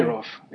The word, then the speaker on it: Kadyrov: Kadyrov. It's Ramzan Kadyrov, yeah.